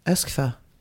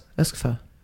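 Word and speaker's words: Euskefeurat: Så här uttalar man det. Öskfö.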